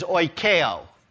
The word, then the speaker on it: oikeo: But to talk about that word, let me tell you how to pronounce it. Okay, in the Greek, the root word for dwell is oikeo.